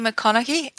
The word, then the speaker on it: McConaughey: You know, the one that I'm quite excited to read is the one by Matthew McConaughey.